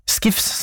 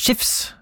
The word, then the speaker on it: Skifs: Unni har skrevet melding om at Bjørn Skifs, som jeg sa, skal egentlig uttales Skifs med H.